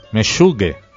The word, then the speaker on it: Meshuge: Meshuge. – Loco, del hebreo meshuga, en pronunciación yiddish.